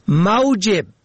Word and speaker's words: موجب: موجب میم واو جیم بی به زم اول سبب، موجب به فتح اول معاش، این دو کلمه نیز کمتر مراعات می شود، در حالی که معنی خیلی متفاوت دارن.